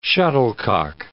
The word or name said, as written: shuttlecock